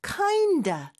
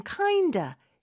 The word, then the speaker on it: kind of: 하나의 단어로 그냥 인식을 해야 된다라고 해주셨는데, 실제로 발음을 할 때도 kinda, of의 f 발음 빼고 그냥 kinda, 이렇게 축약해서 말하는 경우도 굉장히 많습니다.